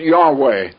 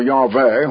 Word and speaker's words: Yahweh: But it was a marriage of carnal human flesh and blood people with the great Christ, who was called Yahweh at that time, or Yahweh, whichever way you want to. A lot of them pronounce the W there as a V anyway, and sometimes they spell it that way.